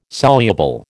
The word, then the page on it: soluble: American English